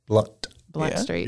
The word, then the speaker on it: Blount: It's not, I don't know, some people call it Blount Street. – People that ain't from here. – Blunt Street. – You don't pronounce it. – It's spelled like Blount.